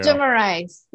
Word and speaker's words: Demarais: Demarais. Like more rice on my plate.